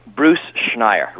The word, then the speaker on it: Bruce Schneier: Bruce Schneier. Rhymes with tire is easy.